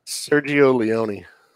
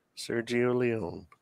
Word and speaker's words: Sergio Leone: I'm saying it wrong, but it's. – Yeah, you are. – Sierra Leone. I don't know how to pronounce it. I spell it. – Sergio Leone. – Yes, okay. Well, I'm saying the last name correctly. – Sergio Leone. I was like, is he saying Sierra Leone? – He is, yeah.